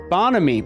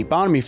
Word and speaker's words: bonhomie: Bonhomie. Bonhomie.